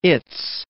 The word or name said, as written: its